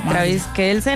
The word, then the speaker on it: Travis Kelce: Travis Kelce, ¿no? Así se pronuncia. – Sí, sí, Travis Kelce es un jugador de fútbol americano.